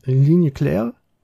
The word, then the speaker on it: Ligne Claire: Ne, der diesen ich werde es wieder falsch aussprechen. Ligne Claire. – Hast du richtig ausgesprochen.